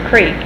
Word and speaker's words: creek: like the word creek and crick. – Mm-hmm. – Up crick. I use the word creek. – Creek. – But crick. I notice a lot of people in this vicinity and in Oklahoma in general say use the word crick.